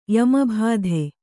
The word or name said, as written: ಯಮಭಾಧೆ